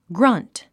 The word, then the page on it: grunt: ɡrʌ́nt; グラァント